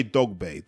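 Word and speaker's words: Dogboe: I should say Dogbe, that's really how they say it.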